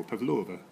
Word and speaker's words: Pavlova: So the stress always on the second syllable, Pavlova.